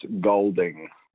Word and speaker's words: Goulding: Brad said it perfectly. It's Golding. We don't pronounce the U.